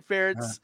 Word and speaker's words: Ferentz: It's Ference, not Ference, Ference. Brian Ferentz uh. – rhymes with parents.